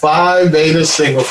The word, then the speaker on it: Phi Beta Sigma: Can you please tell our listener about Phi Beta Sigma Fraternity Incorporated and the charity they do? I don't know if I say it right, but... – You said it right. Phi Beta Sigma Fraternity Incorporated.